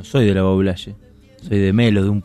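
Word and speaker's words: Laboulaye: Porque acá decimos la Boulaye, pero ustedes dicen la Boulaye. – Sí, claro. La Boulaye. – Así es la pronunciación correcta. – La O antes de la U, la Boulaye. – Toda la vida lo hemos dicho mal. La Boulaye.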